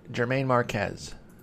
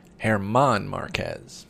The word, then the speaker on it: Germán Márquez: Antonio Senzatella, Kyle Freeland, and Germán Márquez. Wait, I'm saying that name wrong. Hang on. Germán Márquez.